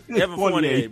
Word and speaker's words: Evan Fournier: Evan Fournier, brother.